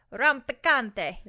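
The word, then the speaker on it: rampicante: How do you say it? Rompicante.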